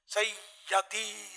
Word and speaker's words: سیدی: سیدی مرشدی, دونوں تلفظ غلط ہیں, یا کے نیچے زیر سید,